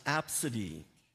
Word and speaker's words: Abcde: The children's name is spelled A-B-C-D-E, and it's pronounced Apsidy.